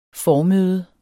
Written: formøde

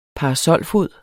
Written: parasolfod

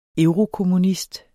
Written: eurokommunist

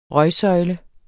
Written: røgsøjle

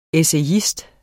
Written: ESSAYISTERNES

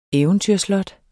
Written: eventyrslot

